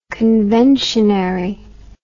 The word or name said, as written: conventionary